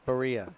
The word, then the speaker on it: Barilla: I know you call it Barilla. I still say Berea. – Berea, because that's how it's said in Italy. – In Italy, it's called Berea. It's number one pasta in Italy, of course.